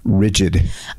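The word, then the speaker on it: Ridgid: What is it? It's rigid. – I thought that was Rigide. – Oh, I've been saying it wrong the whole time.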